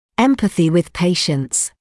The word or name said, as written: empathy with patients